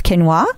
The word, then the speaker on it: quinoa: It's spelled Q-U-I-N-O-A. So before I was edumacated on health foods, I also would have pronounced it quinoa.